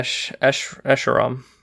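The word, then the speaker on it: Escharum: Yeah, Escheram. Escheram. – Yeah, I think that's how it's spelled. It's 3 syllables, Escheram.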